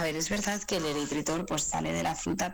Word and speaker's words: eritritol: Es que no sé si lo digo bien, eritritol. – Sí, sí, está bien dicho.